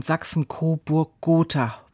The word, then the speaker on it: Sachsen-Coburg-Gotha: Sachsen-Coburg-Gotha wenn ich das jetzt richtig gesagt habe. – Ja, das hast du richtig gesagt und ich bin jetzt auch wirklich gespannt,